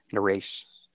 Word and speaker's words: Naresh: Naresh. – Naresh, and your last name? – Vissa. – Naresh Vissa. Am I saying that right or close enough? – Nope, that's perfect.